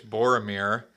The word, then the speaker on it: Boromir: All right, so it's Sauron. It's Gimli. It's Boromir. It's Liv Tyler. I'm just all the mispronunciations you had there.